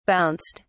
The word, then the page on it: bounced: British English